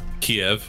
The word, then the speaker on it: Kyiv: Kyiv. – The woman, how do you say it? – Kyiv.